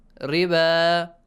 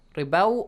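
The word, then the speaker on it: الربا: Riba. There is an alif on the top of waw. Hussein, do you see that? – Yes. – Should we pronounce it as waw, ribaw, or alif? – No, alif.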